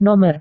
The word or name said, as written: nomor